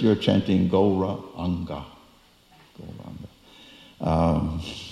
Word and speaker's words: Gauranga: Gauranga. Four syllables. Gauranga. So sure enough,